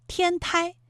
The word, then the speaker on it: 天台: 它还有一个读音啊，是一声胎，台州，这是我们国家的一个地名哈，还有天台，是一个山名，它都在浙江省。